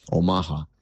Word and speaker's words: Omaha: Omaha es la lengua del nativo Omaha, que es la pronunciación correcta de los nativos en Norteamérica, nativo americano aquí de Estados Unidos. Omaha.